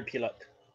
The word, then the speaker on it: Pilote: Am I pronouncing his last name right? Pierre Pilote? – Who? – Pierre, the Blackhawks. Pierre Pilote, is it? – I'm not sure. – Pilote? P-I-L-O-T-E? – Yeah, Pierre Pilote.